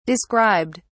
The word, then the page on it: described: British English